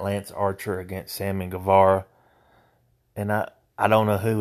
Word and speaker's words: Guevara: Guevara.